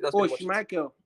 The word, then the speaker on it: Schmeichel: Yeah, my five people are Finn, as we mentioned, Martinez, if not, but I'll have my second goalkeeper option is how do you pronounce this guy's name again? Schmeichel or whatever. Yeah, that's it. – Schmeichel. – Yeah, Schmeichel.